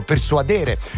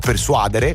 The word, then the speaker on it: persuadere: così come il verbo persuadere con l'accento su lei, anche se molti dicono persuadere, o come io sento dire spesso amaca, vorrei stare steso su un'amaca, godermi un pomeriggio.